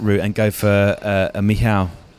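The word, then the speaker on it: Michał: Because I'm very conscious I've been calling him Michael throughout the pod, which is incorrect.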